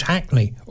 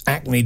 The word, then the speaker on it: Hackney: It was Hackney, or as you pronounce it, Acne Diamonds, mate, Acne.